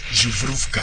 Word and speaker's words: Zubrovka: Me explicó una vez Sergio Pitol que tenía, sí, que pronunciar Zubrovka.